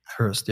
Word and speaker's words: Hirst: Cause or John Curran or Damien Hirst. Did I say his name right? – Hirst, yeah.